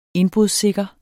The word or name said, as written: indbrudssikre